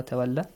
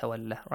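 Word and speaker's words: تولى: So when you stop, do not pronounce that mad. Do not prolong it. Look, Tawalla has a mad.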